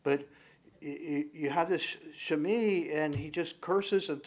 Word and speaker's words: Shimei: Why would God allow a Shammai, or actually probably better pronounced Shimei,